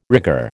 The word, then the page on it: rigour: American English